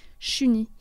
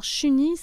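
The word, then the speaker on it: Shuni: Et Julie, c'est un prénom qui serait prononcé Chuni euh, par ses ancêtres autochtones qui n'auraient pas pu prononcer le J et le L. Alors Chuni, c'est Julie en Innu.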